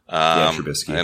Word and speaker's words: Trubisky: Trubisky.